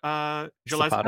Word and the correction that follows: Holy Span: Can you send it? Oh, Holy Span. Oh, yes. – Wow, Holy Span is the name of the city.